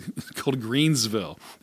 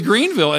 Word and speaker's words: Greenville: So when I'm reading and traveling, I know when I would fly into town, it was always called Greensville. They would always mispronounce it. – You notice that too. – And now it's always Greenville.